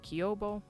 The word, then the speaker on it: quiubo: but we are going to go with Q-U-I-U-B-O, again, Kiobo. Next is the word malparido, spelled M-A-L-P-A-R-I-D-O, and it is translated in the show as meaning motherfucker, but Google Translate says it means bastard.